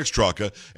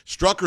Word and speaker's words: Straka: It's Brandon Strock, and it's spelled B-R-A-N-D-O-N-S-T-R-A-K-A. And I pause because it looks like Straka. Everybody's going to say Straka, but it is Strock. It's pronounced Strock or Strock? – Strock. – Strock. Okay, good.